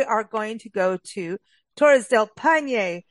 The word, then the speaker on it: Torres del Paine: I think it's Torres del Paine, but I think that's exactly the way you pronounced it, Paine. – Paine, that's it.